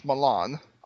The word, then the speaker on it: Milan: I'm from Milan, Indiana, which is, if you ask the rest of the world, it's pronounced Milan.